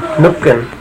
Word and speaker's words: Nukan: Nookin. – Yeah. – How do you spell that? – That's his grandfather. – Nookin, N-U-K-A-N.